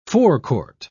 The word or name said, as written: forecourt